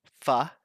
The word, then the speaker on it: pho: Um, so in America, pho, and that is how it's pronounced. And anyone who says pho is just wrong.